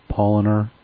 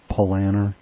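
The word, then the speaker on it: Paulaner: The brewery is Polliner, not Polliner.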